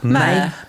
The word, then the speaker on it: mayor: Yeah, you say it like M-A-R-E, like mayor, like a female horse.